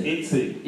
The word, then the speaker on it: Etsy: Etsy. – Etsy.